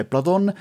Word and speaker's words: Platon: Platon, lo chiamo Platon, ma la pronuncia è Platon, è un tipo di fotografo le cui foto vanno veramente osservate per ore, primo perché sono ricchissime di dettagli.